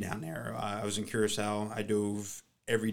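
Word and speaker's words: Curaçao: So you've dove in a couple tropical places, Puerto Rico, Curacao, did I say that right? – Curacao.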